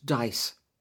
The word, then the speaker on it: DIIS: Another exciting development in Dorset has been the DIIS, Dorset Information and Intelligence Service, pronounced DICE,